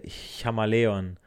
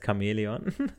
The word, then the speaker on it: Chamäleon: Ähm, erinnert ihr euch so äh, an How mit Met Your Mother, da sagte Ted äh, Chamaleon. Jetzt hoffentlich äh, werdet ihr das jetzt nicht in Zukunft genauso aussprechen, ja, aber es heißt Chamäleon.